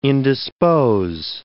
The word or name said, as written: indispose